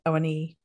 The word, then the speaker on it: Awoniyi: I think it's Mbeumo and Awoniyi who are the two. I'm very sorry if I'm mispronouncing that. – Oh, I looked it up. That is correct. Awoniyi.